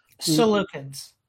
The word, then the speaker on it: Seleucids: But then subsequently, you've got the, what do they call it, the Seleucids? Is that how you say it? – Seleucids.